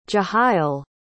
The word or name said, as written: Jehiel